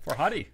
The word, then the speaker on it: Farhadi: Farhadi.